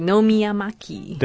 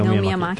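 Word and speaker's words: 野宮真貴: 好，忘记了她的汉字怎么念了。 – 野宫珍贵，Nomiyamaki。 – 对，Nomiyamaki。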